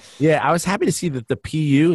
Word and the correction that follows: Pew: is it pronounced PU? PU Research Center? – Pew. – Oh, Pew.